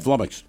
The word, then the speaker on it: flummoxed: Just flummoxed. Rhymes with stomachs.